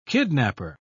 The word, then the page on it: kidnaper: British English